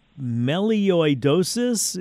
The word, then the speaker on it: melioidosis: Tell me about melioidosis. Is that how you say it? What is this? – You got it. You pronounced it correctly.